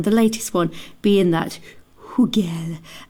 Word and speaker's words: Hugel: Anyway, I went on Google to listen to the correct pronunciation. Listen to this. – French, this is a name of French origin. It's a surname. It's the name of a fairly popular DJ in French. Hugel is the French pronunciation.